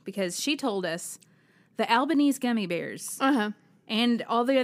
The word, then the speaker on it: Albanese: And we said it correctly. – We did. – It's Albanese. Oh, yes, good.